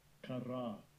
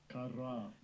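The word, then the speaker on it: Kara (קָרָא): And the name that he is called, the word call, let's hear the pronunciation. – Strong's H7121. Kara. Kara.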